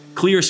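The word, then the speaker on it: Xlear: Clear spelled with an X, X-L-E-A-R, clear nasal spray.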